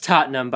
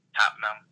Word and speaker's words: Tottenham: Also not it's actually pronounced Tottenham, by the way. – Tottenham. – Yeah. – Oh, Tottenham. Yeah.